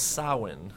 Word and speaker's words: Samhain: Celtic festival is Samhain, sometimes pronounced Samhain, Samhain.